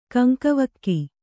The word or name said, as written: ಕಂಕವಕ್ಕಿ